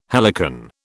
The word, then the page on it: helicon: British English